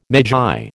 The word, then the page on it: magi: American English